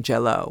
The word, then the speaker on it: Jell-O: my Uncle Bud and Aunt Helen always brought the salad to family gatherings at Lake Harriet, and Aunt Helen's specialty was Jell-O. Emphasis on gel.